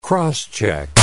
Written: cross-check

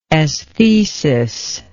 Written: esthesis

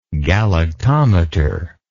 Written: galactometer

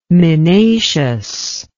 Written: minacious

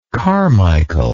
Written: carmichael

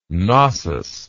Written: gnossian

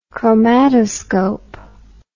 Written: chromatoscope

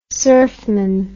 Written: surfman

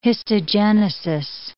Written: histogenesis

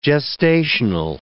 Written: gestational

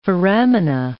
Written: foramina

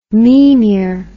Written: mimir

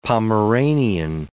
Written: Pomeranian

